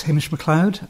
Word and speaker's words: Hamish Macleod: My name is Hamish Macleod.